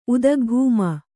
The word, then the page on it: ಉದಗ್ಭೂಮಿ: Kannada